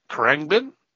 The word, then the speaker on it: Khruangbin: It's spelt Khruangbin. And I think it's pronounced Krangbin because I think it's the Thai word for aeroplane.